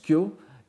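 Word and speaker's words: kyo (今日): Bien qu'il s'écrive avec deux orthographes différentes en japonais classique, kyo au sens de aujourd'hui, ça s'écrit kefu et ça se prononce kyo, et au sens de sutra, ça s'écrit kyau, et ça se prononce aussi kyo.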